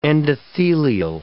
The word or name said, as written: endothelial